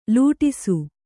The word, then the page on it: ಲಟ್ಟಿಸು: laṭṭisu